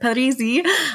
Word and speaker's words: Parisi: Welcome, Ida Parisi, which I always mispronounce. I'm so sorry. Say it with the Italian accent, Parisi.